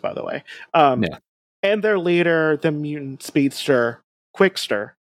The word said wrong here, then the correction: Quicksilver: Quickster. Wow, I said that wrong. mutant speedster, Quicksilver.